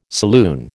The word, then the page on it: saloon: American English